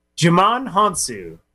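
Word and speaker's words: Djimon Hounsou: Jimon Hunsu. It's like Jumanji. Hunsu.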